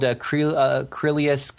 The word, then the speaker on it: Krylius: It's spelled Krylius, K R Y L I U S for Sam.